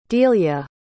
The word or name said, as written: delia